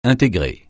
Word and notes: intégrer: francais

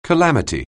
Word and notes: calamity: anglais

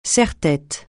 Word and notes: serre-tête: francais

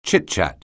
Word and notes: chitchat: anglais

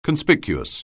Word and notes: conspicuous: anglais

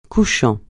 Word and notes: couchant: francais

